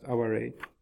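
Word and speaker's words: aware (哀れ): It's sort of transliterated like the English word aware, but pronounced aware.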